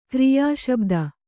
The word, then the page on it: ಕ್ರಿಯಾಶಬ್ದ: Kannada